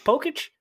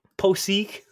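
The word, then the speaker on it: Pocic: How do you say your name? Pokic? Okay, Poseek.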